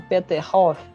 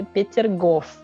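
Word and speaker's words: Peter Gof (Петергоф): entender o que você está falando porque em russo é um pouquinho diferente a pronúncia é, e, algo que é realmente hum, se a pessoa não tipo se esforçar um pouquinho talvez ela não vai nem entender em português em russo é Peter Gof.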